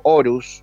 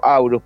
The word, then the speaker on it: Horus: de Aurus, o Horus, yo lo pronuncio Aurus porque dicen que esotéricamente se pronuncia así, pero si quieren lo pronuncio Horus para que no se produzcan eh, confusiones.